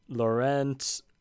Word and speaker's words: Laurent: there is the front runner, like majorly tipped to win it, is Laurent Wauquiez. No, that's not how you pronounce that. Give it a go. – It'll be Lauren. So you don't pronounce the T.